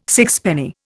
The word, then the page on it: sixpenny: Indian